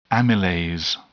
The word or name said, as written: amylase